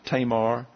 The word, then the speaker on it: Tamar: And by the way, let's call them commentaries and dictionaries that I have accent on the first syllable. You may be calling her Tamar or Tamar, but it's Tamar according to the pronunciations in all of the sources that I have.